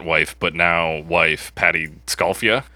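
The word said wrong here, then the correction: Scialfa: Yeah, I think it's just Scalfa. Scalfa, she. – Because I ju- I looked it up, and uh, that was the pronunciation. I think the I is just a just to throw you off.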